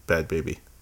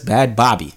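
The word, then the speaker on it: Bhad Bhabie: She said it's literally pronounced as bad baby. Everybody says Bad Bobby.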